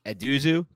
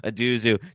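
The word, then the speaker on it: Ezeudu: Eduzu, 22.61. And is that how you pronounce it? – Yeah, I believe so. – Oh, I was nice. I nailed it on the first one. Eduzu, 22.61.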